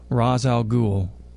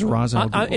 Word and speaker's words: Ra's al Ghul: Is it Ra's or is it Ra's? It's been called, pronounced both ways over the years. – I thought Batman Begins was Ra's al Ghul. – In this postmodern era, I'm going to go with the Ra's pronunciation because I just think it sounds cooler.